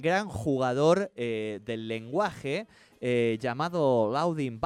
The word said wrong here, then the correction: Ludwig: Porque y fue uno de los que más generó reacciones en, el hilo este, porque Ludwig Wittgenstein, ¿no?